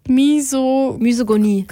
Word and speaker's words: Misogynie: Und da spricht sie eben unter anderem auch sehr viel über feministische Themen und Misogynie. Scheiße, das ist jetzt Misogynie. Das Y wird wie ein Ü ausgesprochen, ne? Misogyn.